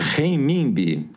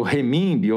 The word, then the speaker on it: renminbi: ele realizou a primeira transação comercial direta em renminbi, que é a moeda chinesa. Né? Fala de novo o nome da moeda chinesa. Renminbi, ou renminbi, ou renminbi. Pode ter as duas pronúncias.